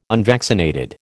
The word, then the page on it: unvaccinated: American English